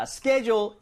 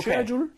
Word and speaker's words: schedule: 근데 이게 발음이 대부분은 schedule이라고 하지만 영국 쪽이나 뭐 이런 식으로 장소에 따라 schedule이라고 읽을 때도 있어요. – 와우. – Did I mention this before? – 아니요, 처음 들어봤어요. Okay. Schedule? – Schedule. Schedule. 그래서 share처럼 발음하는 곳도 있습니다. 오케이.